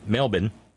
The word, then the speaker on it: Melbourne: See, John Fox tried to tell me how to say that one night. I kept Mebbin, Mebbin, Mebbin. But it's Melbourne. But there's a reason. It's just like C-A-N-B-E-R-R-A is pronounced Canberra.